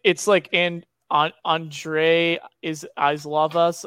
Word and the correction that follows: Iosivas: Uh, it's I, I I butchered that so bad, but like oh he, yeah, it's uh, Andre I Iosivas. – Iosivas, yeah.